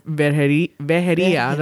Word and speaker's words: Vejería: And that, you know, that reminds me a little bit, too, of what our friend Fred Garza did over at Vejería. That's how you pronounce it in Spanish.